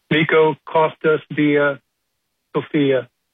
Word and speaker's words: Miko: It is M-I-K-O. It is Miko. Is it pronounced Miko, mm-hmm. or yeah. am I wrong? – Miko, yeah.